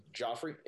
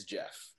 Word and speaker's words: Geoff: Or they'll say my name, Joffrey, it's Geoff.